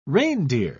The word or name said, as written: reindeer